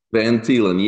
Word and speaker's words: Van Thielen: Did I pronounce that right? – Van Thielen, yes. It's originally Dutch, CJ, but it's good.